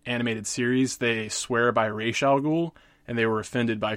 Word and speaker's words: Ra's al Ghul: In this scenario, he goes up against some members of the Light, chiefly Lex Luthor and Ra's al Ghul, as his name is pronounced in this iteration. I'm one of those people. I tend to pronounce it Ra's al Ghul, but I can certainly adapt to Ra's al Ghul.